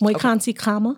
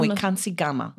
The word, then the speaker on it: Moikansi Kagama: No, not Moikansi Kagama. – Right. Kagama. In English, a G is silent. – Gotcha. – And in Setswana, it's a K.